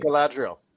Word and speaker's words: Galadriel: Galadriel.